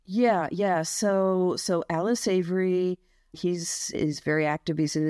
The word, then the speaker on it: Savory: Is his name, do you pronounce it Savory? S-A-V-O-R-Y. – Oh, Alan Savory. – Savory, right. Yes.